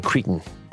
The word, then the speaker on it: Cretan: You say eat like a Cretan. – Yeah. – Did I pronounce it correctly? – Absolutely.